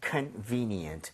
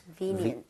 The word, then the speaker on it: convenient: Convenient. – Convenient. – VE에 강세를 짓고 발음하면 되겠습니다. 여러분 같이 한번 발음해 보도록 할게요. VE에 강세주고요.